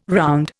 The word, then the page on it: round: Indian